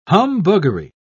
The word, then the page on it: humbuggery: British English